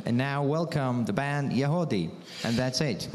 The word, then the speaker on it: Yagody: Y-A-G-O-D-Y, but it's pronounced Yahodeh.